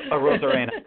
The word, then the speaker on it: Arozarena: And please correct our butchering of how to pronounce his name. But um, it's a Rosarena. – a, Ro- a Rosarena.